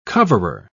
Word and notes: coverer: British English

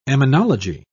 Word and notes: emmenology: British English

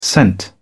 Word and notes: sent: British English

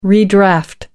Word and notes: redraft: American English

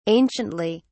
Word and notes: anciently: British English

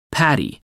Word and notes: paddy: American English

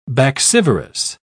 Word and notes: baccivorous: British English

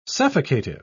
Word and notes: suffocative: British English

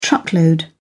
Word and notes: truckload: British English

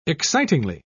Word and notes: excitingly: British English

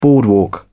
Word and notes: boardwalk: British English

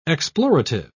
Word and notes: explorative: British English

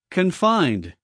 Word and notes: confined: American English

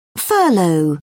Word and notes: furlough: British English

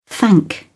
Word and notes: thank: British English

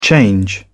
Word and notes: change over: British English

